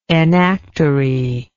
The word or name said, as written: enactory